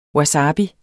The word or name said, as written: wasabi